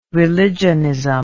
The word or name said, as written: religionism